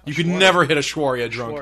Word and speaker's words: Aishwarya: It's not Aishwarya Rai, right? – Oh, I love Rai.